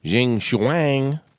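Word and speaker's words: Geng Shuang: Adding tariffs will not solve any problem, Chinese Foreign Ministry spokesman Jing Shuang said at a regular press briefing. I actually pronounced that absolutely correct.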